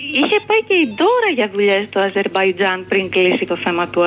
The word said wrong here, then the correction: Αζερβαϊτζάν: Αζερβαϊτζάν, αγάπη μου, Αζερβαϊτζάν. Μίλα το καλά, αφού το λες.